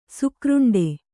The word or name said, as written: ಶಾಖಾರಂಡ